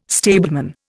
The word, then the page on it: stableman: Indian